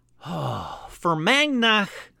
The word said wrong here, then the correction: Fermanagh: And I'm going to pause right here to figure out how the fuck to say Fermagnach. That's right, Stitch. You get mad at me because I pronounced it wrong. Yep. Here we go. How to say it. Hey. – Fermanagh. – Well, yeah, I was way off.